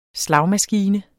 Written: SLAGMASKINE

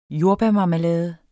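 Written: jordbærmarmelade

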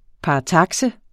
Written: paratakse

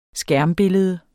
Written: skærmbillede

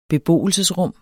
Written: beboelsesrum

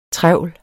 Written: TREVLS

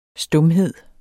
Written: stumhed

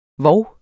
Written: vov

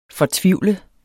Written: fortvivlet

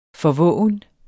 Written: forvoven

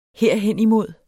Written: herhenimod

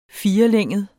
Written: firelænget

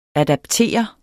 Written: adapter

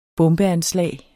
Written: bombeanslag

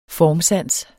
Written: formsans

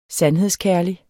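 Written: SANDHEDSKÆRLIGT

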